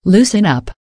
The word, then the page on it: loosen up: American English